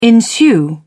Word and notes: ensue: British English

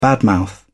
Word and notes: bad mouth: British English

